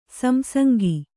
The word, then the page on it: ಸಂಸಂಗ: samsanga